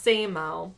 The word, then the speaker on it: SAMO: It looks like SAMO almost, but kinda, it's pronounced SAMO.